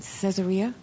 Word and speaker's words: Caesarea: So the second time, friends took me to a place in Caesarea. Am I saying it right? – Yeah.